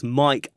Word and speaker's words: MIC: The MIC, pronounced as Mike Like Microphone Coalition, as it says on its homepage.